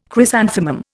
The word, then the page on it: chrysanthemum: Indian